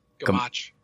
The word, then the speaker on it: Gamache: Gamache? – Gamache.